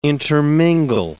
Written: intermingle

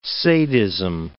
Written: sadism

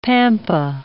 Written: pampa

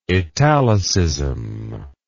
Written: italicism

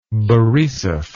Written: Borisov

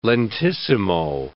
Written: lentissimo

